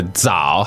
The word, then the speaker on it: zao: Z-A-O, third tone. Zao. Zao. – Now, the Z in pinyin is not just a zz sound. It's actually a DZ sound, right? – Dzz.